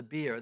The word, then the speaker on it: bier: And so Luke tells us then that Jesus comes and touches the bier. That's how it's pronounced. In this case, a bier is not a drink.